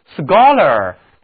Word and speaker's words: scholar: 我们给表示学习的词根 schol 加一个名词后缀 ar，同样 ch 读音还是需要浊化成 g。请跟我读 scholar，它的意思是学者，有文化的人。